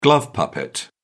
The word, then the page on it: glove puppet: British English